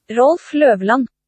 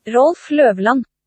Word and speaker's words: Rolf Løvland: It looks like Loveland, but I know the O is not pronounced that way. So here is, I believe, the pronunciation of it. This is what I found online. – Rolf Løvland. – Okay, that is how to pronounce his name.